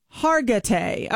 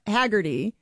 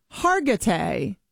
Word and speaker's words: Hargitay: Her name is Mariska Hargitay. Okay, so it's not Haggerty. It's Mariska Hargitay.